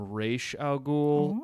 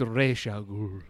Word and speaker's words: Ra's al Ghul: but I lean more towards the whole you're supposed to call him Ra's al Ghul. – I'm going to wag that around. – Sue me. Christopher Nolan, pronounce it however you want. But every time they say it, I'm like, it's Ra's. It's Ra's al Ghul.